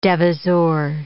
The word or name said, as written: devisor